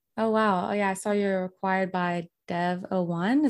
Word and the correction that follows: DV01: Is that how you say it? – DV01. Yes. – DV01.